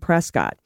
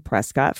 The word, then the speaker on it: Prescott: So when our son was born, he became Prescott Francis blank. Needless to say, we do not pronounce his name to rhyme with biscuit, though my uncle, who lived in Sun City, Arizona, since the late 60s, did.